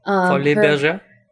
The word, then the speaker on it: Folies Bergère: During her early career, she was renowned as a dancer and was among the most celebrated performers to headline the Folies Bergère, which is in Paris, and that's probably not how you say it. – Folies Bergère? – Yes, that sounds better.